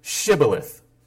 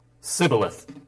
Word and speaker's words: Shibboleth: If he said no, then they would say to him, "Then say Shibboleth." And what he would say is Sibboleth, for he could not pronounce it right. That means he couldn't say the SH sound.